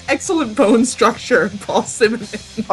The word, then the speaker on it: Simonon: Simonon. Simonon.